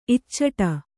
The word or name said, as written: ಇಚ್ಚಟ